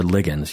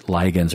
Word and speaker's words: ligands: Or ligands. You can pronounce it ligands or ligands.